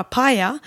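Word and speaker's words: Mpya: Och man ska egentligen uttala det bara med ett tyst M, bara paya.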